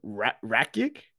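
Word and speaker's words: Rakić: So Yuri Prochazka and Alexander Rakic. Okay. – Easy. It's like Radich, but with a K.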